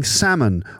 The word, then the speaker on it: salmon: plus the pros and cons of eating salmon on a regular basis. That's the fish, right? Salmon, not salmon. Okay, not the L is silent.